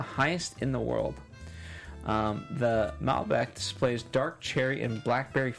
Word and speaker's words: Malbec: And I pulled up Malbec. Mm-hmm. I'm saying that right. – Yes.